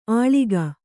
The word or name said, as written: ಅಳೀಕ